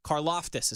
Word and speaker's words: Karlaftis: Karloftis is how you pronounce his last name.